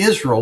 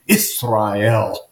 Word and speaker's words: Israel: Why not just say Israel like you've always said? There is no need to change the pronunciation to Israel.